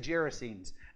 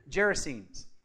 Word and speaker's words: Gerasenes: It's actually Gerasenes. Now, you look at that and you go, how do you get Gerasenes? Like, I don't know. I've heard several people pronounce it that way. So we're going to go with that, all right?